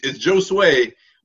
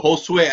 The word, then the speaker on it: Josue: But it's Josue, but Josue, I get that too, of course, because I'm Latino. So, you know, I get both. Either one is cool, though.